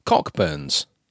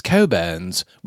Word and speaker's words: Cockburn's: And the Russian ambassador referred to this as Cockburn's. And the English dude says, oh no, it's Coburn's. We don't pronounce the CK.